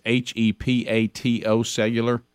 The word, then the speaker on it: hepatocellular: It's HEPATO cellular. Mm-hmm. Is that heptocellular? – Cancer. – Hepatocellular is what is the okay. phonetic spelling.